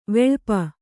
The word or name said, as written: ವಿಳಾಪ